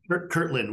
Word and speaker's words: Kirtland: Kirtland with a T, Kirtland Air Force Base here in Albuquerque, New Mexico.